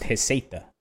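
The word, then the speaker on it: Receita: And also it's pronounced Heseta.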